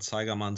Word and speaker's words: Zeigermann: So, as you already said, I'm Oliver Zeigerman. So my last name is extremely hard to pronounce for everyone but me or other German people.